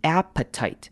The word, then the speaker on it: appetite: 那 appetite 稍微注意的是前面开头的 a 短母音 a 的声音，appetite。 Appetite，好，以及这个字呢，后面的 T-I-T-E 的第二个 T 可以稍微弱化，我们可以不用念成 appetite，可以念成 appetite，这样就好了。